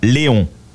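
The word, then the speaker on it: Léon: So in French, you would say Leon.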